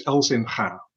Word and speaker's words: Elzinga: In Dutch is Chris Elzinga.